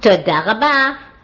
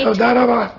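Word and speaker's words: Toda rabba (תודה רבה): Toda rabba. – Toda rabba? – That's right. – Toda rabba.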